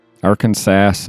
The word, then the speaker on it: Arkansas: Arkansas is pronounced Arkansas and not the way it is spelled. You know, you don't pronounce the S at the end.